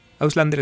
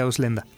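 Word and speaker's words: Ausländer: Auslander está bien, pero eh, en, teoría se pronuncia Auslenda.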